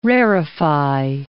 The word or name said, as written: rarify